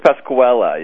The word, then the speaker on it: Pasquella: Because it is Drew Pasquale show, isn't it? – It's Pasquella, yes.